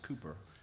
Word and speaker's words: Cowper: William Cooper was, I believe that's how you pronounce his name, it looks like Cowper, but it's, I believe it's pronounced Cooper, was a man who struggled with depression.